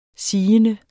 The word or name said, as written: sigende